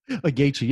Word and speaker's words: Gaethje: And actually, I'm seeing here that that Cerrone he fought both Ferguson and Gaethje or whatever. Well, how do you say his name? – Gaethje.